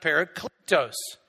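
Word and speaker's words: parakletos (παράκλητος): The word advocate comes from a Greek word that's pronounced parakletos.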